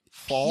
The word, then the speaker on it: Pfohl: Fall. – P-F-O-H-L.